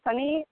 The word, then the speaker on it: Sunny: The name is actually Sunny, S-U-N-N-Y, pronounced.